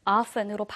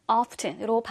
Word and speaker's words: often: 북미 영어에서는 주로 이 T 발음이 사운드가 나지 않아요. 그래서 often, often으로 발음되기도 하지만 영국에서는 often, often으로 발음이 되기 때문에 그 두 가지 것 같이 기억하면 좋겠어요.